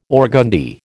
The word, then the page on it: organdie: American English